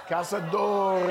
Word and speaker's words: Cazadores: But before we get started, Cazadores. – Cazadores. – That's how we're going to pronounce it from here on out.